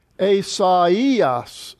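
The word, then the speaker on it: Esaias (Ἠσαΐας): You have your accent over the iota, But these two dots over the iota means that this diphthong, alpha iota, which is naturally translated I, these vowels are now split. Esaias, so they're pronounced separately.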